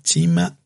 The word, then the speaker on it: cima: Pues eh, en Génova hay un plato muy parecido que se hace también con matambre, con el mismo corte, que se llama chima alla genovese. Chima se escribe cima, C-I-M-A.